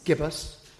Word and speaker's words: gibbous: Is that how you pronounce it? Gibbous? Gibbous? Gibbous. Thank you.